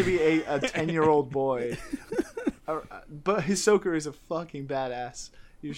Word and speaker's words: Ahsoka: Ahsoka? That's how you say it? Yeah. – Yeah, Ahsoka.